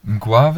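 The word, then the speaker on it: Mgła: MG und dann so ein komisches L, das durchgestrichen ist, A. Ähm, Mgua wird das ausgesprochen, bin ich mir ziemlich sicher.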